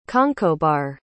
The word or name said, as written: conchobhar